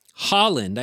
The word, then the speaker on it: Haaland: There's Deanne Criswell, who is the head of FEMA, and Deb Haaland, I believe you pronounce that, H-A-A-L-A-N-D, who is the Secretary of Interior, who oversees the Bureau of Land Management.